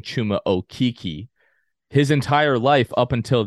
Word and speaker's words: Chuma Okeke: It's Chuma Okeke, and I thank you for letting me know.